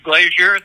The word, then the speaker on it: Glazier: My name is Richard Glazier. That's the last name is spelled G-L-A-Z-I-E-R.